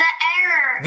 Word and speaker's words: heir: So an heir is H-E-I-R with a silent H inevitably there.